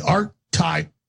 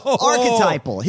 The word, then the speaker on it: archetypal: But Schiff looks like the archetypal cocksucker. – Archetypal. The word he wants is archetypal, right? – Well, yeah.